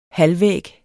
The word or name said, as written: halvvæg